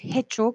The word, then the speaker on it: hecho: No vamos a decir hecho con la H, recuerden que la H no se pronuncia.